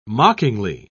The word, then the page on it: mockingly: British English